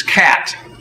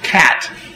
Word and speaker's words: ChAT: The way you properly pronounce this is cat. It doesn't look like the word cat you learned in kindergarten, but neuroscientists tend to be spelling impaired. So cat. CHAT, choline acetyltransferase.